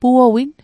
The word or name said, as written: puowin